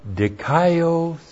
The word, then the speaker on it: dikaiosune: But now, these weapons of the royal family of God are weapons of dikaiosune, weapons of righteousness. D-I-K-A-I-O-S-U-N, long E. Dikaiosune, righteousness.